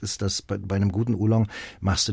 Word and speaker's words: Oolong: Dann habe ich ihn auch ganz falsch ausgesprochen. – Oolong.